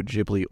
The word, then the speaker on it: Ghibli: It is the precursor to all the Studio Ghibli or Ghibli, depending on how you decide to pronounce it, films.